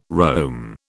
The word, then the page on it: roam: British English